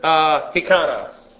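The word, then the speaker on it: hikanos (ἱκανός): I am not, and then this word, hikanos. H-I-K-A-N-O-S. Hikanos, actually. The accent is on the last syllable.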